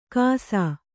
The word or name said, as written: ಕಾಸಾ